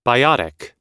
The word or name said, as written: biotic